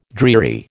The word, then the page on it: dreary: American English